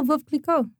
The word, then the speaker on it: Veuve Clicquot: How do you pronounce V-E-A-U? Second word, C-L-I-C-Q-U-O-T. – Oh, Veuve Clicquot.